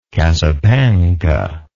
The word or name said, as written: cassapanca